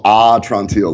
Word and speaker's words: Atrantil: Yeah, it's called Entrantil, like my belly's better. – Entrantil.